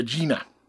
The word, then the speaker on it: Agena: I picked up more stuff about the Agena. – Okay. – And I'm relatively certain that's the way you pronounce it. A-G-E-N-A.